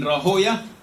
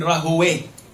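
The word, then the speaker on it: Rahwayh: You can say Ishaq ibn Rahoya, and you can say Ishaq ibn Rahoway, like Sibawe or Siboya.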